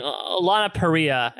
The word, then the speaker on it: Lana Parrilla: And I mean, Lana Parrilla, and I thank you to the Twitter user who pointed out that I was pronouncing that incorrectly previously.